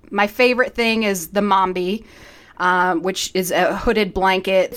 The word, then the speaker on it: Mambe: Okay, so Mombi, what we're talking about, it's spelled M-A-M-B-E.